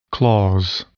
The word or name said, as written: clause